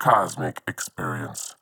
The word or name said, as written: Cosmic Experience